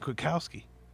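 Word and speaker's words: Kwiatkoski: He's Nick Kwiatkowski. He will. Like, it rhymes with Rob Gronkowski.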